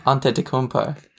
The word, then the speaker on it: Antetokounmpo: Antetokounmpo. – Greek freaks, brother. – It's not even that hard to say. – Go slowly for me. – Antetokounmpo.